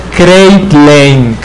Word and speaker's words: Kreitlein: Lo arbitró Rudolf Kreitlein. – De Alemania Federal. – No, sería veras la pronunciación. Kreitlein.